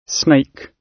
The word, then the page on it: snake: British English